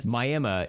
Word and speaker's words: Miami: Real quick, while we're speaking about Miami, for listeners who are not from Oklahoma, Miami is spelled Miami, but if you say Miami there, they will break out the torches and pitchforks and come for you.